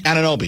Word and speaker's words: Anunoby: Oh, Anunoby? – Anunoby. Is that how you pronounce it? – Yeah.